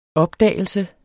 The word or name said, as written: opdagelse